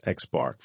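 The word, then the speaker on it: x̄: Exhibit 8-55 and Exhibit 8-56 show two types of control charts developed for the same set of observations, an X chart, pronounced X bar, for the sample mean and an R chart for the range of variation in the samples.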